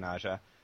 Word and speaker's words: Aja: We had, let me see if I say this right, Aja. Mm-hmm. Is that how you say it? – Yeah, Aja.